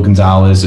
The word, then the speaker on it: Gonzalez: My name's Angel Gonzalez.